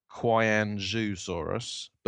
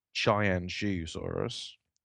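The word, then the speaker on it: Qianzhousaurus: This is called, it's spelt, if I was a dumb English person, I would say it Kyanzhusaurus, but apparently it's pronounced Chianzhusaurus sinensis, and it's an Allioramus-like tyrannosaurid published by Juncheng Liu and colleagues in Nature Communications, brand new paper.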